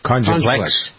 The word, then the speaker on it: Conjuplex: And now I take probably 30 different supplements a day that are all, like I take Conjuplex. I don't know if I pronounce it right. Conjuplex. – Yep.